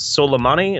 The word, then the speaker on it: Soleimani: goes and kills Soleimani.